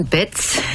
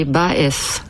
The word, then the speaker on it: Baez: In Germany it's Joan Baez, and in France it's Joan Baez, and in Spain it was originally Baez.